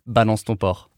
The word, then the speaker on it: Balance ton porc: balance, like it would be the word balance, T-O-N-P-O-R-C. Balance ton port. Or how would you say it? – Balance ton port.